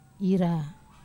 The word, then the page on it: ra: wamesa